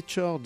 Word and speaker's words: Chords: Les Chords, les Chords, c'est comme ça qu'on les prononce, avec un extrait d'une face B d'un single qui s'appelait Don't Go Back.